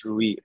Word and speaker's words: Ruyi: Our website, so the restaurant name is Ruyi, pronounced as R-U-Y-I.